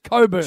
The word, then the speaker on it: Cockburn: It's pronounced Coburn. – How do you spell it? That's a question. – No. – C-O-C-K. – I don't care. – That's cock to me. No. B-U-R-N. Burn.